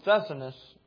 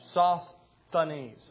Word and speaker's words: Sosthenes: And so Sosthenes, our brother, and I know I'm saying that name wrong. So what's that? One more time. Sosthenes? I have to pause. Sosthenes. I have to add a little pause there.